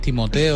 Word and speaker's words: Timothée: Timothy, a veces francés, y es Timothée, o sea, que lo que tú quieras. – Es Timothy, se produce Timothy, creo yo.